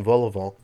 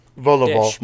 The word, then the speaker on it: vol-au-vent: vol-au-vent. – Yes. – I love that. I just felt ignorant because I didn't know how to say it aloud. Chicken vol-au-vent.